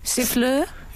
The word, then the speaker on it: siffleur: Siffleur? – Is that right? – How would you say it? – Siffleur. Yeah.